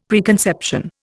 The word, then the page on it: preconception: Indian